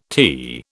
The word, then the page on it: tea: British English